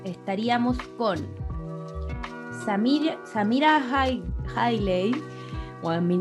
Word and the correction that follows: Samira Wiley: Ya tenemos Samira Wiley, creo.